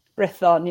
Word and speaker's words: Brython: So you've got Brython Thunder and Gwalia Lightning. – Brython, yeah.